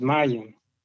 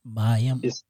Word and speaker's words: mayim: Now, when you deal with waters, the Hebrew word for waters is mayim. It's plural. It has an I-M on the end of it. – Mayim? – I-M. Mayim. It's spelled M-A-Y.